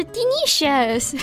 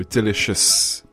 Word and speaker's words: delicious: So if you do want to use the word delicious, you should have heavy emphasis on the D and the sh noise. So delicious Delicious.